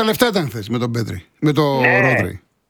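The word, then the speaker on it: Πέδρι: Πέδρη, το τονίζω, Πέδρη, Κομπαρσί που είναι 18-19 χρονών, πόσο είναι.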